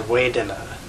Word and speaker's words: Vedana: So there's this realm of what is called in Pali, Vedana or Vedana. Usually V's are pronounced as W's in Pali. But for some reason, Vedana is usually gets a V sound, most people.